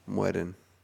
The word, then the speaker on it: mueren: Las leyendas nunca miren. – Mueren. – See, there you go.